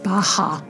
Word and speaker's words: Baja: Baja. – Baja, thank you. Baja, California, in Mexico. Vaquitas have the smallest range of any whale, dolphin, or porpoise. Right there in that northern area of Baja, California is where you're going to find the vaquita.